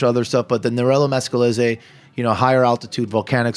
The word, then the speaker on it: Nerello Mascalese: Nerello Mascalese is the grape. So it's right. hard to pronounce for some people. – M-A-S-C-E-L-L-E-S-E.